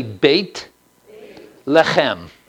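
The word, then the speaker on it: Beit Lechem (בית לחם): Say Beit Lechem. Say Lechem again. Okay, good. I just like to see you spit. Forget the perfume. I'm having him spit here, Matthew, okay? So Beit Lechem, the house of bread.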